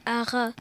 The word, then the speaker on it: Arras: On ne disait pas le S à la fin d'Arras, ce qui donnait à rat.